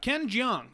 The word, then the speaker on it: Ken Jeong: Ken Jeong. Is that how you pronounce his name? – Yep.